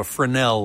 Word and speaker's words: Fresnel: But if you're looking straight on, They had to do a special coating on this screen called a Fresnel lens, spelled F-R-E-S-N-E-L, but the S is not pronounced.